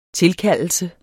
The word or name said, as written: tilkaldelse